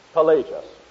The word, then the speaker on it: Pelagius: His name is spelled P-E-L-A-G-I-U-S. Pelagius.